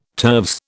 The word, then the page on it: turves: British English